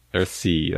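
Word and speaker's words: Earthsea: Earth Chite, arrive. – Earth Sea, that's right, Earth Sea.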